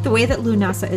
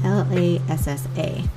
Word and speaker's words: Lughnasadh: Because like most Celtic names, it's not phonetically said the way that it's spelled. And so the way that Lughnasadh is spelled is Lughnasadh, Lughnasadh, or also known as Lhasa, Lassa, which is much easier to say if you are, you know, American like myself.